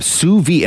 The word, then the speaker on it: sous vide: Sous vide, and it's spelled Sousvide.